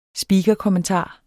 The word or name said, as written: speakerkommentar